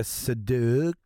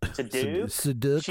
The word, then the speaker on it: Seydoux: It's, Lea, you say her name? Seydoux. – I'll get it here. Let's do this right. Lea Seydoux. – Seydoux.